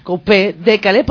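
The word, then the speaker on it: Coupé-Décalé: A Kofi y a Carlota, que nos vienen aquí, que es lo que habéis escuchado, un tema musical de Coupé de Calais, no sé si lo he dicho bien. – Sí. – Coupé de Calais, porque además tiene dos acentos, el de Calais, es como un momento ahí.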